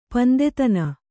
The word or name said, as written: ಪಂದೆತನ